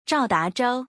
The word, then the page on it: 赵达舟: Zhao